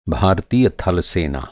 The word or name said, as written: थल